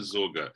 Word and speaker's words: Sorge: is it Sorge? – Yes. – How do you pronounce the name? – It's Richard Sorge, the S-O-R-G-E.